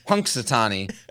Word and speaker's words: Punxsutawney: Do you know how to say this groundhog's name? – Punxsutawney Phil. Do you really know how to say it? – Punxsutawney Phil. – Punxsutawney. – I always struggle with that. – Punk, yeah. – Like a punk.